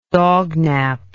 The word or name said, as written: dognaper